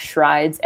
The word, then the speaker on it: Schreids: You can find me on Twitter at Shrides, S-C-H-R-E-I-D-S. – Screeds. – Screeds. – Sorry. – Exactly. It's okay. I've been pronouncing my name wrong this whole time.